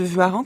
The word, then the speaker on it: Warens: C'est ce que ça s'écrit W-A-R-E-N-S. – C'est d'abord W-A, c'est une faute de transcription déjà en Savoie, parce que le vrai nom de famille c'est V-U-A, donc c'est Vua, et puis en Savoie, Reims se dit Rang.